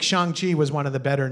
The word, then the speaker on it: Shang-Chi: We skipped The Incredible Hulk, Iron Man 3, Thor Dark World, Guardians of the Galaxy, Ant-Man and the Wasp, Black Widow, Shang-Chi or Song-Chi? I don't know how to pronounce it. – Song-Chi.